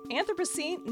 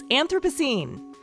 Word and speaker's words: Anthropocene: Which brings me to my first question, and it's Anthropocene. No, wait. Anthropocene. It's Anthropocene. I'm Beth Golay, this is Marginalia, and here's my conversation with John Green about The Anthropocene Reviewed.